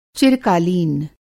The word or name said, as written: चिरकालीन